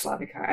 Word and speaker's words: Slavica: So because I grew up in Australia, and you know, Australians have a really hard time with pronouncing foreign names, as I'm sure you know. – Yes. – With your background as well. So I was always Slavica, and you know, I have no problem with that.